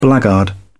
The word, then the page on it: blackguard: British English